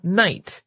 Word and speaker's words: night: But the early modern period is also when we stopped pronouncing the K, the G, and the H in the word night.